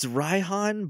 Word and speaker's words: Raihan: And then Ryan. It's Raihan, but I just pronounce it as Ryan in my head because that's what it looks like to me.